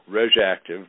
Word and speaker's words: RegActiv: The name of this brand name of product is called RegActive. That's spelled RegActiv.